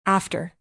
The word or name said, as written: after